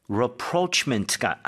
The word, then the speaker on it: rapprochement: 발음할 때 다시 한번 rapprochement가 아니고 rapprochement Rapprochement.